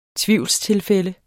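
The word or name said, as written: TVIVLSTILFÆLDENE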